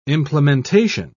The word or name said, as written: implementation